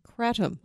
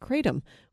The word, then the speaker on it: kratom: This week, the Food and Drug Administration issued an advisory about kratom, also commonly pronounced kratom, which is an herbal supplement that's used to treat pain, anxiety, depression, and addiction.